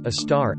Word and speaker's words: Sagittarius A*: Some examples are Sagittarius A**, that's pronounced a star,